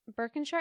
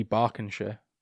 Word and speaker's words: Berkshire: Just Berkshire? Is that how you say it? – You never say Shire.